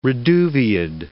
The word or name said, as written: reduviid